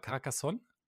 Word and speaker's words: Carcassonne: Carcassonne? – Äh, Kenne ich. – Habe ich es richtig ausgesprochen? – Hätte ich jetzt gesagt, ja.